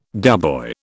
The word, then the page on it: doughboy: British English